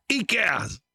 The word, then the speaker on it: IKEA: ¿Lo dije bien, Miquea? ¿O Ikea? Y yo dije Miquea. ¿Ikea? Bueno, ¿Miquea o Ikea? ¡Ikea!